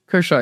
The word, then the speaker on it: Kershaw: Kershaw, yeah.